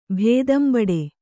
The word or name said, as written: ಭೇದಂಬಡೆ